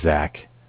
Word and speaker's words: Zach: My name is Zach, spelled Z-A-C-H. – Z-A-C-H?